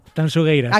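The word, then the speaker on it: Tanxugueiras: A ver, repítemelo, como tengo que pronunciarlo bien. – Tanchungueiras. – Sí, esto me lo corrige Fermín Agustín mucho. Marta, por favor, digo, mira que yo siempre pronuncio... A ver, otra vez, dímelo. – Tanchungueiras. – Acaba de deshacerse la manifestación a las puertas de la Gran Vía, de lo bien que lo has dicho.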